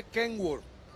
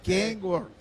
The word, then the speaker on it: Kenworth: Y se dice Kenworth. – ¿Eh? Kenworth. – Kenworth. – Kenworth. – Es King, no King. Es Kenworth. No, sí.